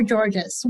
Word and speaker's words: Georges: My name is Tess Vergara, Soul Powered Executive Coach, and I am with a very special guest, George or Georges. What do you prefer? – It's a George. The S is silent. It was written in the French, actually.